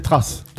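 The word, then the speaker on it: Thrace: Alors, déjà, se demander si ça se prononce trace ou autre chose, ça s'écrit T-H-R-A-C-E, c'est ça ? – À la française, c'est trace. – D'accord. – Voilà. – Et donc, on a trouvé que c'était un peuple des Balkans. Et en fait, on a surtout trouvé un truc trash sur les traces là.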